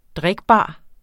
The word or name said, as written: drikbar